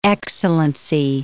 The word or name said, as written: Excellency